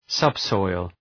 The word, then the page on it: subsoil: ‘sʌbsɔıl